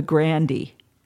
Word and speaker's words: Grande: one of his clients was Russ Gibb, who was the manager of a ballroom like the Fillmore called the Grandy, Ballroom, and it's spelled Grande.